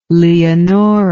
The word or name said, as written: Leonora